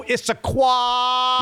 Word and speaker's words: Issaquah: Go Issaquah.